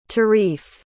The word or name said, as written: tarif